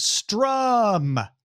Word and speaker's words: Struum: Former executives from Discovery and Disney are launching a streaming service called Strum. I'm saying it that way because it's spelled S-T-R-U-U-M. But Strum will not have its own content.